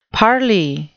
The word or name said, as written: parley